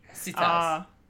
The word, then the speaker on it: sitars: A lot of Bon Jovi on scimitars. That'll be... – Sitars. – Sitars. – Sitars. – Yeah. – Sorry, I added a syllable. Just me adding a syllable.